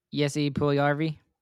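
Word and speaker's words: Puljujärvi: I think Paul Yuliarvi. Is that how you say his name? – Jesse Puljujarvi.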